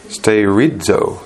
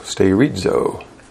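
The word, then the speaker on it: sterizo (στηρίζω): And it is the Greek word pronounced sterizo, sterizo. And it's spelled in the Greek S-T-E-R-I-Z-O.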